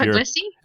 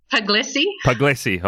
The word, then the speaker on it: Puglisi: Becca Pugliese is name of Puglisi. – How do you pronounce that? – Puglisi.